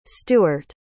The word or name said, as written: stuart